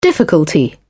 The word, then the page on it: difficulty: British English